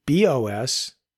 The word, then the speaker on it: BeOS: Quite some years ago, I even gave BOS, that's spelled BEOS, a go once, and it showed great promise, then kind of died.